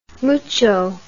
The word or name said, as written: mucho